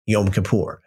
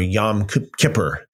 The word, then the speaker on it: Yom Kippur: Yom Kippur, um, or Yom Kippur. Some people pronounce it like that, Yom Kippur.